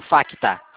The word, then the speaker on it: Facta: Isso, Facta. F-A-C-T-A. O C é mudo.